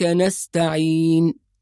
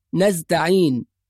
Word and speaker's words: نستعين: أيضا نجد البعض يقرأ إياك نعبد وإياك، نعبد وإياك، يسكن الدال، والصواب أن الدال مضمومة، نعبد، إياك نعبد وإياك نستعين. أيضا بعض الناس ينطق السين زاي فيقول نستعين، وهذا أيضا خطأ، يبقى لازم نحافظ على حرف السين.